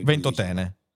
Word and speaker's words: Ventotene: Infatti devo dire con grande felicità, eh, ecco felicità è una parola eh, eccessiva, mi rallegro che il gruppo chiamato Comitato Ventotene o Ventotene, io non ho mai capito lì dove vada l'accento. – Ventotene. – Ventotene,